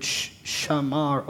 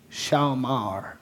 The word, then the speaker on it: shamar: It's the Hebrew word shamar or shamar, however you want to. It's spelled S-H-A-M-A-R or S-H-A-W-M-A-R.